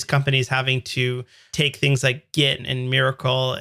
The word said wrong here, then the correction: Mercurial: and how do you say that? Mir- miracle? – Miracle? Mercurial? Mercurial. – Mercurial. I always goof that up.